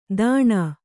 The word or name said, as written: ದಾಣಾ